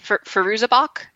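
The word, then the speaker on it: Fairuza Balk: I will say one thing that works for me is, and I'm maybe going to say it, Firouza Balk. Is that how you say her name? – I believe so, yes.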